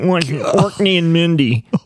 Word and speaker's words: Orkney: Are you sure you're saying it right? – Yeah, I actually went. It's Orkney for sure.